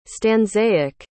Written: stanzaic

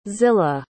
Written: zilla

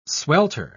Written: swelter

